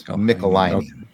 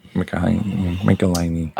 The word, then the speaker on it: Michelinie: And they wrote back, it rhymes with pickle miney. – So it's Michelinie. – Michelinie.